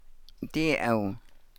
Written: dii aww

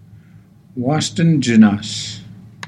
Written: waasdan janaas

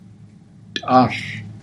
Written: taahl

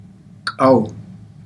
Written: kaaw

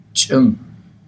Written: ts'ang

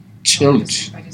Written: ts'alj

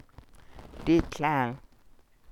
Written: dii tlaal

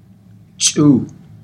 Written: ts'uu

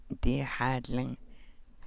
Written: dii xáadlang

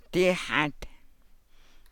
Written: dii xáad

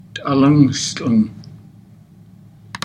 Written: taalan stlang

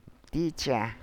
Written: dii jaa

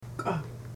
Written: kah

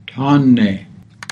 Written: taan nee

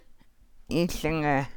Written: iihlangaa

